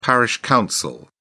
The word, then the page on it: parish council: British English